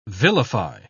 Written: vilify

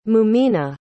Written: mumina

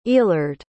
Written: eallard

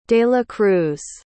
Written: delacruz

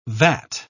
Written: vat

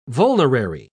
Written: vulnerary

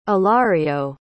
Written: ilario

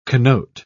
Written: connote

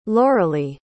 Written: loralie

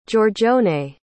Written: giorgione

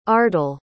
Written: ardell

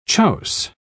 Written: chouse

